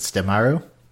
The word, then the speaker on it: Sutemaru: It's it's it's spelled Sutemaru, but I think they I, am trying to remember. How do you remember how they pronounce it? Stemaru.